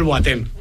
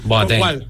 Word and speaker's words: Boateng: que no se pronunciase igual Boateng.